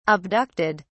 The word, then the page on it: abducted: British English